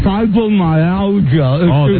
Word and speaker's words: fibromyalgia: It's called fibromyalgia. – It's painful. – You ever heard of it? – Pronounced it pretty well.